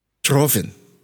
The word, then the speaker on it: Trofin: How do you say it? – In Romanian? – Yes. Say it again. – Trofin.